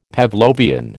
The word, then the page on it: Pavlovian: American English